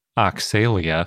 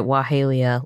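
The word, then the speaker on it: Oxalaia: We also have Dinosaur of the Day, Oshalaia, which we usually pronounce Oxalia. – I think I pronounced it Wahalia last time, and that was also wrong. – So we're all over the map on the pronunciation of this one. It's spelled like Oxalia. It's O-X-A-L-A-I-A.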